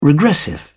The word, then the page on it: regressive: British English